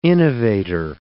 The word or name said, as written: innovator